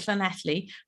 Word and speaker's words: Llanelli: And my fun fact is I live in a town called Llanelli, which has far too many consonants.